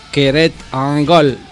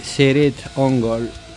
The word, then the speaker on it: Cirith Ungol: La forma correcta de pronunciar el nombre es Cered-Ungol, pero la banda lo pronuncia con un suave Cered-Ungol. Cered-Ungol para los amigos.